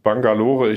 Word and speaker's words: Bangalore: Neu-Delhi, Hyderabad und Bangalore.